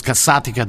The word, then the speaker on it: Casatica: I pronounce it Casarica. But then people come and tell me it's Casatica. It's not, right? I can stand by that when yes. people come up to my counter and tell me I'm not pronouncing it right. So it's Casatica di Bufala, right? – Yeah.